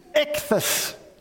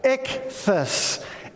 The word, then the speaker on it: ichthys: Okay, fish in Greek is ichthys. Can you pronounce that? Ichthys. Ichthys. Yes, that's the Greek for fish.